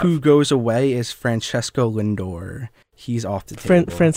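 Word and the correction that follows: Francisco Lindor: Francisco Lindor.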